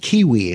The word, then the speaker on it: Qiui: Uh, the name of the product, I think it's pronounced it's pronounced Kiwi. It's spelled Q I U I.